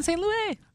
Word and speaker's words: St. Louis: I want to know that transition because you went to, I don't know if I'm saying it right. Is it St. Louis? – Yeah, it was in St. Louis.